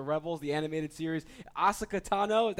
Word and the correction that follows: Ahsoka Tano: that's the right way to pronounce it? – Oh, no, it's hard. It's Ahsoka Tano. – Ahsoka Tano, thank you. – That's okay. Everyone mispronounces it.